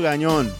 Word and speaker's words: Gagnon: Pedro or Pedro Gagnon.